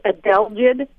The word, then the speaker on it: adelgid: My question is, I have hemlock trees, and I've lost quite a few to the woolly adelgid. – Mm-hmm. – I don't know if I'm saying it correctly. – Yep, you are, you did, yep.